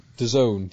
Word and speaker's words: DAZN: How do you pronounce it? – DAZN. – DAZN. D-A-Z-N. – That sounds better.